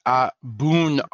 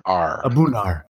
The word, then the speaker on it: A’bunadh: I still can't pronounce it right. – Yeah. Whatever. – I'm going to go pick it up later. – It's A’bunadh. – A’bunadh.